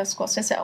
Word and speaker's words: Scorsese: Scorsese klingt besser, aber er wird leider Scorsese ausgesprochen.